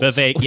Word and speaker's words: Vivek: Vivek is not one. – It's two syllables. – Vivek, exactly.